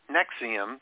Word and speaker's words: NXIVM: and Nexium is spelt N-X-I-V-M, so it's pronounced Nexium, as if it's N-E-X-I-U-M, but it's spelled N-X-I-V-M, and if you've wondered, did I see that somewhere?